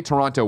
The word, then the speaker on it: Toronto: Basically, a week from tomorrow, me, Nick, and Andy are headed over to Toronto. We're going to get in there. You got to drop. That's, they say Toronto weird. We say it Toronto. They're like Toronto.